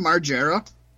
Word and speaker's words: Margera: Is that how you pronounce his name, Margera? – Yeah.